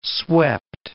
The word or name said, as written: swept